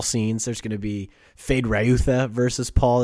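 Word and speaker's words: Feyd-Rautha: Fade Rayutha.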